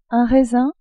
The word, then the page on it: raisin: French